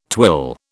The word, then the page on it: twill: British English